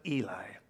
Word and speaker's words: Heli: And Jesus himself began to be about 30 years of age, being, as was supposed, the son of Joseph, which was the son of Heli, or as we would pronounce it, Eli.